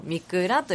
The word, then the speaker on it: 御座: でその仙宮ですね、仙宮ならぬ仙座というのがありまして、でその仙座をする御蔵祭っていうのがね、お座りになるって書くんですけど、お座りになるじゃないな、おってなんて言えばいいの、御中のおに座るで、御座って読むんですけど、御蔵と読みまして、それ御蔵祭っていうのがありましてね、そこで歌を奉納、曲を奉納させていただきました。